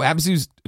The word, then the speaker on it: Abzu: Big thing, and a callback to our topic one, right, is I play Abzu. Am I saying it right? – Abzu.